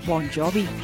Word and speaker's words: Bon Jovi: por lo que simplemente lo hizo sonar anglosajón al cambiar la G por la J y pronunciarlo Bon Jovi.